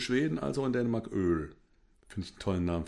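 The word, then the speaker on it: Øl: Nee, dieses, dieses O mit, mit Querstrich durch und dann L. Okay. Ausgesprochen Öl.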